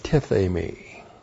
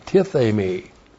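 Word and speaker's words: tithemi (τίθημι): And then we have the verb tithemi. T-I-T-H, long E-M-I, tithemi.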